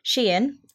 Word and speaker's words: Shein: So the online fast fashion brand Shein, which I've only discovered that's how you pronounce it, I've been pronouncing it wrong for ages.